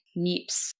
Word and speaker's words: Niépce: It's spelled N-I-E-P-C-E, Niépce.